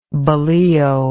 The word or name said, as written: bolillo